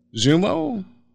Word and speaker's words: Xumo: But new customers, and I'm not sure how you pronounce this, X-U-M-O, Xumo? – Xumo. Yeah, it's a Xumo box.